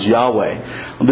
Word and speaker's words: Yahweh: There's no vocalization, there are no vowels in the Hebrew text. And so all we're left are the radicals, of which we translate as YHWH. And we supply the vowel sounds, Yahweh.